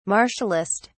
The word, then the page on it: martialist: British English